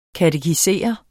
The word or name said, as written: katekisere